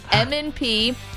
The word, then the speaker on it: M&P: Some people think that's pronounced Manned P. M&P.